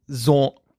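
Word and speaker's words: Xorn: It's X-O-R-N. So we've been calling him Vaughn, but it's Zorn. – Oh. – Corn? How do you pronounce that? – Zorn. – Zorn.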